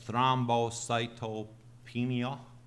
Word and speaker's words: thrombocytopenia: There has been, and I don't know how to pronounce this, it's low platelet, but it's thrombocytopenia. Is that how I pronounce it? Thank you.